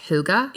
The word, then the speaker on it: hygge: So, like, hygge. – Yes, hygge. – It's hygge. – Oh, it's hygge. – Yeah. It's spelled H-Y-G-G-E. – And it's pronounced hygge? – Yeah, I promise.